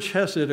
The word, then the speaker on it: chesed: Hebrew word in English is spelled C-H-E-S-E-D, chesed, C-H-E-S-E-D. And it means kindness, mercy, loving kindness, unfailing love, tenderness, and faithfulness.